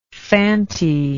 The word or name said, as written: fanti